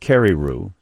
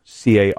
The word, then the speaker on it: Carreyrou: John, I think his last name is pronounced Carreyrou, Carreyrou, Carreyrou.